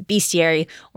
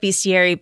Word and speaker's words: bestiary: It's hard to know how to pronounce this one because I've heard it pronounced so many different ways. We're going to go with bestiary because that's, I think, an easier way for people to see how it might be spelled if they want to look for it later on.